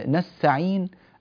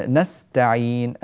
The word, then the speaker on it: نستعين: هذا جاء في كلمتين، نستعين المستقيم، نستا مستا، إيتي بالسين بشكل صحيح وكامل ثم أتي بالتاء بشكل واضح وتام، لأنه يطلع معك أحيانا النطق نسعين المستقيم. وهكذا يعني نقع نحن في مشكلة، تدغم الحرف السين في التاء هذا لا يصح. نستعين المستقيم، وانتبه لهذه الملاحظة وأكتفي بها إن شاء الله تعالى.